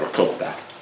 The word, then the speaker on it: ricotta: And I appreciate the, as an Italian, the way you say it, ricotta.